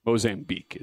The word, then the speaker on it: Mozambique: Mozambique is how that is pronounced.